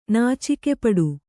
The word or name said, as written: ನಾಚಿಕೆಪಡು